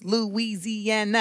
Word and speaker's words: Louisiana: Y'all, Louisiana has five syllables. Louisiana.